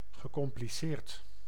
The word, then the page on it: complexe: France; [kɔ̃.plɛks]